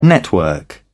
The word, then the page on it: network: British English